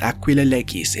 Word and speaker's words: Aquila Leges: pronounced Aquila Leges in Latin for Eagle of the Law,